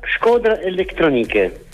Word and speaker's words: Shkodra Elektronike: Poi invece ho scoperto tanto altro, per esempio scoperto che ha il suo progetto Skroda Elettroniche, lo pronuncio bene? – Quasi, 99% l'hai detto giusto. – Pronunciacelo tu. – Skoda Elettroniche. – Io ho detto Skroda, ok, Skoda, ho messo male le R.